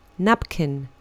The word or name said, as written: napkin